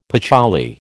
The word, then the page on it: patchouli: American English